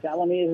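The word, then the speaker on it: Tallamy: Talamay is right. – Talamay? – Talamay. Yes. – Oh, Talamay. Okay, good.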